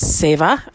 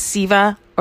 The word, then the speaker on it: Seva: It's Seva. I have pronounced it the wrong way for a very long time. And so I'm trying to retrain my brain. I've heard it pronounced Siva or Seva. Most often I've heard it pronounced Seva.